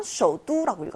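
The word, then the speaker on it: 쇼두라고 (首都): 수도는 베이징이지만 쇼두라고 읽었어요. 뚜라고 읽는다는 거 발음에 유념해 주시고요.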